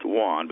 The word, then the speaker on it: Yan: We think it's pronounced Wan, but it's spelled Y-A-N.